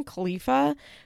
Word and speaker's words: Khalifa: How do you say her last name? Khalifa,